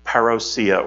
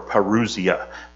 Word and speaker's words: parousia: It's the word that in the Greek, the word is parousia or parousia, P-A-R-O-U-S-I-A, parousia or parousia, depending on how some people you read how they pronounce it.